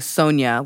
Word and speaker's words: Sonja: Like where they use like, if I was saying Sonia, like Sonia Morgan from Real Housewives of New York. Like Sonia is spelled with S-O-N-J-A for the ya sound.